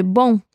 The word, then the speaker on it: bom: Atención a la pronunciación de bom. Es como si le agregaras una U a la palabra después de la O y terminaras con la N.